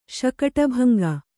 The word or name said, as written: ಶಕಟಭಂಗ